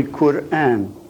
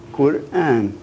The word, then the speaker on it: Quran: It's actually Quran. And this is a long A, Quran.